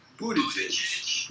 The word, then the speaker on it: Buttigieg: But it's like, yeah, he's a booty judge. So I don't mean it in that way. I mean, that is the best mnemonic to understand how his name is pronounced. Buttigieg.